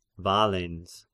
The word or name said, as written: Valens